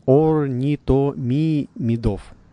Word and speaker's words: орнитомимидов: Сегодня он вам расскажет, кто похож на птицу, но не птица, про авирапторов и орнитомибов, орнитомимидов, если я правильно произношу. Поправьте меня, Ярослав Александрович. – Да, все правильно.